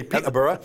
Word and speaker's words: Peterborough: They pronounce it a little bit, they say Peterborough.